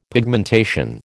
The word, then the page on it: pigmentation: American English